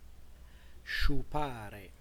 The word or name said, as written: sciupare